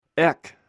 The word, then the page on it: ek: greek